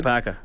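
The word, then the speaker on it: Jokipakka: And then, what's his name, Jokapaki. Is that how I pronounce it? – Jokapaka.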